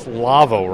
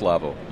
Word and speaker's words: LAWO: LAWO, a lot of people on this side of the pond. It's LAVO, right? – Correct. Yep, it's pronounced LAVO. You bet.